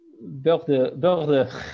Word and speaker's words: Bordeaux: Sorry for my pronunciation. I don't know if French. Girondin Bordeaux. Let's just say French, Bordeaux. Yeah. Bordeaux is fine. – Bordeaux. – Yeah, see, that's better than mine.